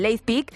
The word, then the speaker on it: Leipzig: Lo he dicho bien, Leipzig. – Leipzig. – Leipzig. Leipzig. – Bueno, Leipzig. Si lo dices tú, Leipzig. – Que termina en G.